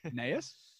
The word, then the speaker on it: Gnaeus: Gnaeus starts with a G. – Yeah, it's true. – Silent G.